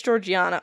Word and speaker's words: Georgiana: I can't fucking say her name correctly. Georgiana? – That sounds better. – That's it. It's Georgiana. Okay, so Georgiana.